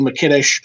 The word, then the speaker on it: Mikitish: You know, my name is Joe McKittish. You know, my last name is spelled M-I-K-I-T-I-S-H.